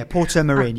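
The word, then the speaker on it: Portomarín: Oh, and again, I don't remember the name of it, but it was this incredible restaurant in Port Myron. I'm probably not saying that right either. – Yeah, Porto Marin, yeah.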